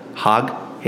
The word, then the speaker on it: Haag: H-A-A-G. Is it Haig? You think it's Haig? Hog? Haig? I think it's Haig. I think it has to be Haig.